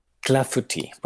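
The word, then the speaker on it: clafoutis: Clafouti. – Clafuti, pues exactamente. – con esa pronunciación, eh, pues es muy fácil.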